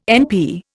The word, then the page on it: NP: Indian